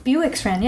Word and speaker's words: Bewick's Wren: And, for example, we have Bewick's Wren. Am I saying that right? – Bewick's Wren, yeah.